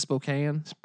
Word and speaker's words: Spokane: They go up to Spokane. – Yeah. – Is it Spokane? – Spokane.